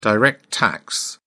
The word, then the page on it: direct tax: British English